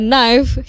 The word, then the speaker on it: knife: Kalau itu tuh biasanya lebih ke lagu kadang ada like if you want to pronounce knife itu ya knife, hilangkan k-nya jadi knife.